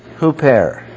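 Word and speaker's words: huper (ὑπέρ): First of all, by the Greek preposition huper, pronounced huper. huper.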